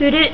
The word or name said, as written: furu